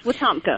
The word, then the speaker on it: Wetumpka: Uh, yes, Wetumpka.